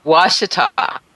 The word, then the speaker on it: Ouachita: Ouachita.